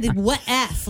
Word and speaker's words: Wuf: It's a little off. It's all right, though. It's Wuf Pet Spa, like when they bark, or Wuf. It's W-U-F. – That's right. – I thought you said Wuf, like a last name.